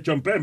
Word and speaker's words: Sean Penn: No, pero es Chompen, ¿verdad? Que pronuncia Chompen. Sí, Chompen. Igual, en eh, usted lo está pronunciando correctamente.